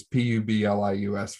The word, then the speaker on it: Publius: I'm going to butcher that name. It's Publius for those at home.